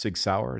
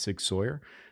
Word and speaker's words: Sig Sauer: And by the way, that is how it's pronounced, Sig Sauer. It's not Sig Sauer.